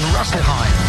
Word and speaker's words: Rüsselsheim: Harold Hummel in Rüsselsheim. That's how you pronounce that.